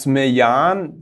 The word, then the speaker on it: Dagsmejan: Also der Brand heißt Mejan. das spricht man auch so aus, wie es geschrieben ist, ist ein schwedischer Brand, steht eigentlich für zwei, für zwei Wörter.